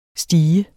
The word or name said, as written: stigen